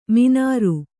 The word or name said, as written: ಮಿನಾರು